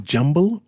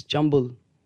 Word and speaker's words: jumble: Is it Jumble or Jumbo Sale? – It's Jumble. – How do you spell that? – J-U-M-B-L-E. – Really? – Yeah. – All my life I've been saying jumbo.